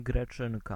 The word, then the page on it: grec: France (Île-de-France); [ɡʁɛk]